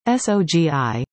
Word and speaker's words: SOGI: SOGI, pronounced SOG, stands for Sexual Orientation and Gender Identity, and SOGI Inclusive Education exists in all BC school districts including School District No.